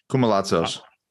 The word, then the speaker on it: Koumalatsos: Koumalatsos. – I'm going to actually write it down. Kuma. – K-O-U.